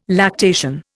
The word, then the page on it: lactation: Indian